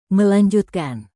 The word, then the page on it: melanjutkan: Indonesian